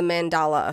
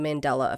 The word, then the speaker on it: Mandela: Number one is the Mandela effect. I'm sorry, I said that wrong. The Mandela effect. I always say mandala because I color mandalas.